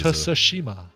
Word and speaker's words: Tsushima: Yeah, I think it's Tsushima is the pronunciation. Tsushima. I think the T is Tsushima.